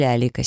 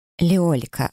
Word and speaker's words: Леолика: Тарк Солод или Алика Серая. – Леолика, автоматически поправила я, и стушевалась, когда он перевел взгляд темных глаз на меня. Ударение на «о», но можно просто «лика».